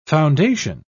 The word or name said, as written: foundation